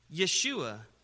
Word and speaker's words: Yeshua: Now, in the Hebrew, it's Yeshua. Jesus is a transliterated Greek version of the Hebrew Yeshua.